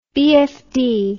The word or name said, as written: BFD